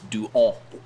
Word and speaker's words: Duhon: But if you go to France, I believe it's Duhon. – Duhon. – Right. – Duhon. – Yeah, because H is not Y in real French. H is not pronounced.